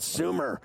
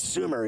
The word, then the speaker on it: Sumer: So I said to Coffee, I go, I think it's Sumer, because last year I butchered it. I was like, here's Thomas Dimitroff from Summer Sports. And you're like, it's Sumer, idiot.